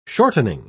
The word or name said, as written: shortening